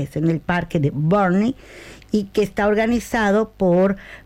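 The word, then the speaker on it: Burnie: Barney, que se dice Burney. Ajá, pero sabemos que se escribe Burney, ¿ok?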